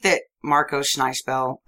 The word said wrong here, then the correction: Schnabel: ever... – Schneisbell. – How you say his name. – Might as well be. – Schnabel. – Schnabel was ever like, Marco Schnabel!